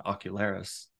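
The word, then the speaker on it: ocularist: And like I said, I would never have predicted that a conversation with what I now know is called an ocularis. Did I say that right? – Yes. You did.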